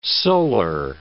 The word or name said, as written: solar